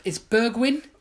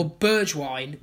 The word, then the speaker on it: Bergwijn: All right, it's Bergwijn or Bergwijn, which is like Genuine, who sang Pony.